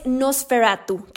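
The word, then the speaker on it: NOS4A2: El siguiente título es Nosferatu, que se escribe NOS 4A2.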